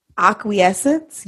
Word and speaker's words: acquiescence: And it said accepting what is, and it was about the art of, let me see if I can pronounce this right. Acquiescence. Yes, that's how you say it. I looked it up.